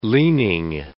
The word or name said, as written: leaning